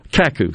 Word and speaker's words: Kaku: You know, the futurist, by the way, Machio, what's his name, Raku? I think I said that right. Kaku, excuse me. I always want to say Raku. Kaku, K-A-K-U.